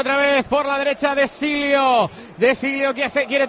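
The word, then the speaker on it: De Sciglio: No, se dice de Silio, dice Marcos. – El sonido glio del italiano no lo tenemos en el castellano y puede ser de Silio.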